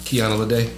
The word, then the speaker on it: Kiana Lede: Kiana Lede.